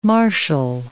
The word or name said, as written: marshal